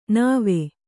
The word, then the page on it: ನಾವ: Kannada